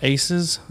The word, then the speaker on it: ACEs: So today we're going to be talking about ACEs. Am I saying that correctly? – That's right. – Like mm-hmm. – ACE, like a card.